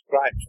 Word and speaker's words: gripes: Handy grips with them. Well, actually, it's gripes phonetically, but that word means to clasp hands together, hand to hand, to go mortal hand to hand combat, so to speak.